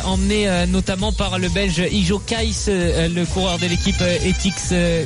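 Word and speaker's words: Iljo Keisse: Je ne sais pas pourquoi Jérôme se marre, ça doit être ma prononciation de Ijo Kaïs, le coureur belge de l'équipe Etix Quickstep, qui était un équipier. – Comment dire C'est parfait comme ça.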